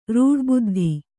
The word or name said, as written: ರೂಢಬುದ್ಧಿ